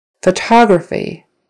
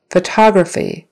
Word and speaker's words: photography: Photography. Photography. Now it's on the second syllable. Photography.